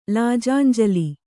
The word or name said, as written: ಲಾಜಾಂಜಲಿ